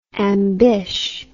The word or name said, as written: ambish